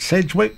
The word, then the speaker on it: Sedgwick: Bacon has been married to actress Kira Sedgwick since 1988. Did I say that right? Sedgwick? Yes.